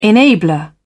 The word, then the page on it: enabler: British English